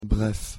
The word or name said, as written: bref